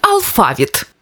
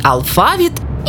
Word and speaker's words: алфавіт: Алфавіт чи алфавіт? У цьому слові подвійний наголос, тому правильно і алфавіт, і алфавіт.